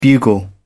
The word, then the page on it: bugle: British English